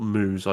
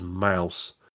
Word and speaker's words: MOUZ: It's not Moose, I do apologise. It is Mouse, spelled M-O-U-Z. That is what threw me.